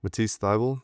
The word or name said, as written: Matisse Thybulle